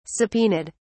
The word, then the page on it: subpoenaed: British English